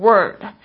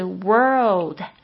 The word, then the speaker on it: world: World, W-O-R-L-D, 很多同学都发不好这个字，为什么呢？因为它有一个 r 这个卷舌音，加上这个 u 这个翘舌音哦，所以呢，大家就是在发音上就是常会念错，会念成 word。其实呢，你注意听一下，其实应该是 world, OK, 而不是 word。